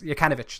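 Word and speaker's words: Jokanović: Oh, it's Yankovic is in charge. Yes, Jokanovic. That's how we pronounce it.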